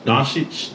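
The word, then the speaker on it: Dončić: Luka Doncic. – Doncic.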